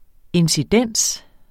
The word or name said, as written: incidens